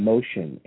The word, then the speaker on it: Mocean: She owns a company called Music in Motion, M O C E A N, phonetically spelled, um, uh, pronounced. Anyway, I'll get you all the information as it becomes available, and you'll be able to uh, join me on the cruise.